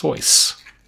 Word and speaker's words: Seuss: They suggested his name be pronounced Seuss, which, if you take the German approach, makes sense.